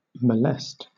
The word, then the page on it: molest: British English